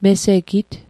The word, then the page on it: mesegit: Mi'gmaq